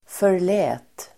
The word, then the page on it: förlät: för_l'ä:t